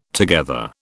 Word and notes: together: British English

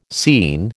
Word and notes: scene: American English

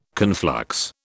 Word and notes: conflux: British English

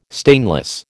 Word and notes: stainless: American English

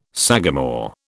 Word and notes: sagamore: British English

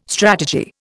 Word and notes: strategy: Indian

